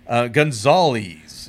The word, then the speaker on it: Gonzales: Like you can have the I pronounce my name Gonzales. – Yeah. – You know, like a news anchor.